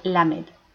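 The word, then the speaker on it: Lamed (למד): que en hebreo se pronuncia Lamed.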